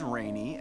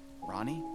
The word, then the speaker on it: Rani: Her name is Rainy, and that's spelled R-A-N-I, and make sure you pronounce it right, too, because she hates that. Wait, Rainy? Ronnie? Damn it.